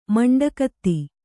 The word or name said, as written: ಮಂಡೆಕತೆ